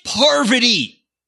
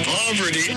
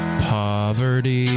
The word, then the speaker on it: Parvati: Please stop pronouncing it. Poverty.